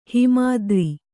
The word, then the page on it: ಹಿಮಾದ್ರಿ: kannada